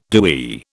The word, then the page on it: DoE: British English